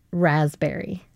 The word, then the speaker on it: raspberry: Raspberry. – Can you spell it now? – Yeah, because it's the silent P that got me. Why does it have a silent P? – You didn't get the raspberry. – No, I got B-E-R-R-Y.